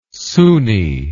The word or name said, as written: soony